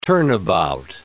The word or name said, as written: turnabout